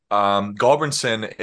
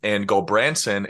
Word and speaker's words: Gulbranson: So my name is Nils Gulbranson, per se. Gulbranson is how my dad says it. And Gulbranson is how my mom says it, because she says that it sounds prettier and she likes Branson, Missouri.